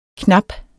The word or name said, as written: knap